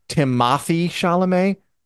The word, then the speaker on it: Timothée Chalamet: No, by the way, his name is pronounced Timothy Chalamet. – Not Chalamet. He's not an elf.